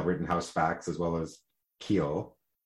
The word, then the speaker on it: Khill: Am I pronouncing it right? It is just K-H-I-L-L, Khill. – I think that counsel pronounced it Khill, but Khill? Okay. – The media seems pronouncing it as Khill.